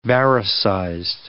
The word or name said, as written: varisized